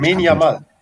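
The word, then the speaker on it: Lamine Yamal: Lamine Jamal.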